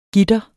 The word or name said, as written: gitre